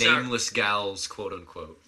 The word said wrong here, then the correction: ghoul: Ghouls. Gals. – You know, gals. – Ghoul. – I don't know how to pronounce that word, but now I do.